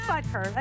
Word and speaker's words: Butker: Harrison Butker from the Kansas City Chiefs. Do I have that right? Oh, I have a cheat sheet. Wait, it's on a paper towel. I can't read it. – Can you read it? Yeah, it is Butker. I said it right.